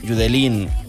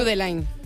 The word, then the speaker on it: Judeline: Pues entonces Judeline. Judeline. Si es de Barbate, Judeline.